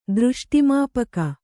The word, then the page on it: ದೃಷ್ಟಿಮಾಪಕ: Kannada